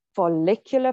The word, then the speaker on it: follicular: So then after that, you go into your follicular phase. Yes, that's how you pronounce it.